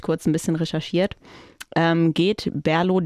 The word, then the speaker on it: BRLO: Genau, von Berlo habe ich ein schönes helles mitgebracht. Eine Berliner Craft-Bier-Brauerei. Geschrieben auf der Flasche steht aber tatsächlich nur BRLO.